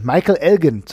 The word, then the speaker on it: Michael Elgin: Michael Elgin zum Beispiel, Elgin, Michael Elgin zum Beispiel. ja. Jetzt habe ich es richtig gesagt.